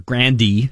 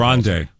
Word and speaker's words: Grande: So Ariana Grande, or Grandy, as Linda Grande.